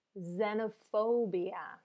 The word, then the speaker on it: xenophobia: Listen first, because this X again is pronounced different than the X in X-ray. This is pronounced, so listen two times, xenophobia. Xenophobia. So there's a z again, right?